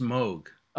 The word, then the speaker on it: Moog: No, they're both pronounced Moog. – Oh, okay. But, we screwed it up then. – No. But we've always... as a musician and a fan of the synthesizer, I always said Moog, but his name is pronounced Moog.